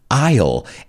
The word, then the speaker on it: aisle: Aisle. And I think the word is very known. The problem there is the pronunciation. It's got that silent S in there.